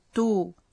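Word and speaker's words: 十: 10 est un cas unique. Il ne prend pas de tsu et se prononce comme to.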